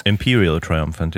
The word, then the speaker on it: Imperial Triumphant: Spricht man das so aus? Imperial Triumphant, ja.